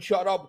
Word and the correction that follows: shout-out: ¿Cómo se dice un shout-up? A Denis Rivera. – Shout-out.